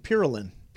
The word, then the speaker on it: Pirulin: P-I-R-U-L-I-N, Pirulin.